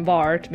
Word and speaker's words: VART: But var t is too hard to say for me, so I just call it VART. And then, like, people laugh because it rhymes with fart, and I think that's hilarious because I'm 9 years old.